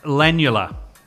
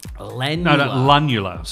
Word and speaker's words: lunula: I can see why they changed it, Lanula. – Lunula.